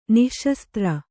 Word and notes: ನಿಃಶಸ್ತ್ರ: nih śastra

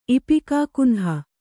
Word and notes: ಇಪಿಕಾಕುನ್ಹ: Kannada

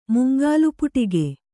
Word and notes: ಮಂಗಳಪಾಠಕ: mangaḷa pāṭhaka

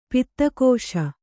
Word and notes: ಪುದುಗಿಸು: pudugisu

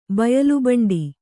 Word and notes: ಬಯಲುಬಂಡಿ: Kannada

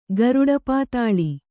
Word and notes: ಗರುಡಪಾತಾಳಿ: garuḍa pātāḷi

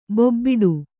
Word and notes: ಬೋಬಾಟ: bōbāṭa

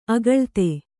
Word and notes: ಆಕುಲಿತೆ: Kannada

